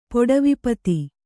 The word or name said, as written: ಪೊಡವಿಪತಿ